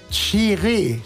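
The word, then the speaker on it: Kyereh: Abu Hanna kam rein, Faber kam rein und Daniel Kofi, Achtung, Chiré, spricht sich das aus, Chiré geschrieben, Chiré,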